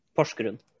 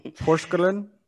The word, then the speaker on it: Porsgrunn: And how do you pronounce that? – Porsgrunn. – I'm not. Nope. I don't want to butcher that. Porsgrunn? – Yeah. It's pretty good.